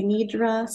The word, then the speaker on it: Nidra: The pronunciation varies depending on who you are, where you're from. I say Nidra. Some people roll the R. It varies. Okay.